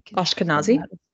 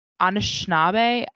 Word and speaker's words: Anishinaabe: And it's about this Ashkenabe. Sorry, I think I mispronounced it. – Ashkenazi? – No. Anishinaabe. I'm not.